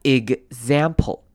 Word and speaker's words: example: 那来看首先开头的 e 呢，我们这边会比较像是念成短母音 i 的声音，念成 it。然后呢 x 呢，我们会发 g，先发 g 的声音，再发 z，所以会发 example。